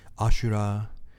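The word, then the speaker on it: Ashura (阿修羅): Ashura uh, is the Japanese pronunciation of it, and these are uh, so heaven is is pleasure, right?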